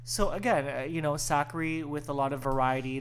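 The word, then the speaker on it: Sakkari: I wanted to mention, because, you know, we can't go an episode without mentioning one of our original OG girls, Sakari, rhymes with Daiquiri.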